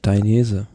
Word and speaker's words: Danese: Ja, aber ansonsten, wie spricht man das richtig aus, Danese? – Danese.